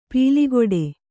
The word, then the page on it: ಪೀಲಿಗೊಡೆ: pīligoḍe